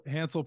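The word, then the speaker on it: Hansle: On your Instagram, you posted a picture with another runner, if I'm pronouncing his name right here, Hansley Parchment. Is that correct? – Hansel Parchment. Excuse me. Sorry for the mispronunciation.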